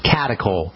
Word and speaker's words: catechol: Catechol? – Catechol. C-A-T. E-C-H-O-L, catechol estrogens.